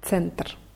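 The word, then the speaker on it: Center (центр): Das Wort Center wird mit Z ausgesprochen. Center.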